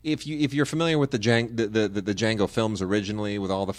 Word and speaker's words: Django: Now, you know how Django... – Yes, D is silent.